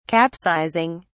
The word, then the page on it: capsizing: British English